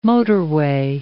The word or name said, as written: motorway